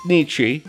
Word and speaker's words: Nietzsche: Is it pronounced Nietzsche or am I stupid? – It's Nietzsche.